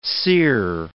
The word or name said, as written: sear